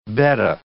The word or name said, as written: betta